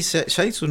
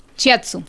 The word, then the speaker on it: Shiatsu: Que hablaba, había uno de Shaitsu, era con puntos, Shiatsu, donde sí. – Que es una, ¿se dice así? Shaitsu, no. – Shiatsu. – Shiatsu, que es japonés esa técnica.